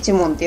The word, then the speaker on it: 朱蒙: で種猛と書いてチモンって読